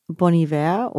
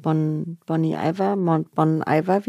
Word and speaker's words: Bon Iver: Ja, also Bon Iver oder manche sagen Bon Iver. Wie wird da jetzt genau ausgesprochen? – Er ist ja Kanadier, deshalb kann man, glaube ich, beides sagen. Es ist Französisch und Englisch.